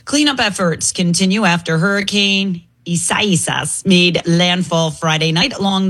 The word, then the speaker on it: Isaias: Isaias. Just say Isaiah with an S at the end. Isaias. – Isaias. – Isaias.